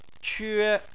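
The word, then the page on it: que: tone 1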